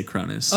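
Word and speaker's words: Kronos: I think we'll just jump right into Captain Cronus is how they say it in the movie. But I think that Canadians say it Cronos. – Yeah, that's just how it's spelt. So I read it Captain Cronus when I first read it. But every time they pronounce it in the movie, they say Cronus.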